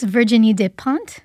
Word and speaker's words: Virginie Despentes: Book number three, we have Pretty Things by, now you'll have to help me with the pronunciation here, Virginie Despentes? – I think it's Virginie Despentes.